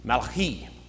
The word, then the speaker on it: Malachi: In Hebrew, it's Malachi, which is my messenger. The E sound at the end of the Hebrew word is the personal possessive my.